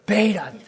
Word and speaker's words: Bethe: The idea that hot fusion was happening in the sun, and what was happening was 1930s Hans Bethe, B-E-T-H-E. How do you pronounce B-E-T-H-E? Bethe? I don't know how to pronounce his last name. Beta.